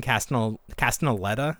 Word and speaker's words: Castellaneta: And then they had Dan Castellaneta. Is that how you pronounce his name? – Yeah, sure.